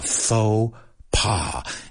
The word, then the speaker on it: faux pas: What a faux pas. And again, no pronunciamos la X ni la S porque es una palabra francesa.